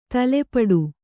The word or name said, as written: ತಲೆಪಟ್ಟು